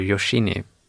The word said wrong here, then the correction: Yoshino: Mm. Yoshino.